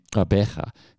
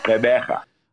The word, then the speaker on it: Gqeberha: Gqeberha. Have I got the pronunciation right? – Close, but no cigar. Gqeberha.